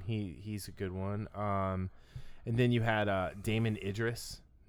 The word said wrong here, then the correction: Damson Idris: Damson Idris. I said his name wrong.